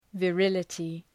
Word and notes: virility: və’rılətı

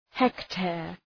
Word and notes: hectare: ‘hekteər

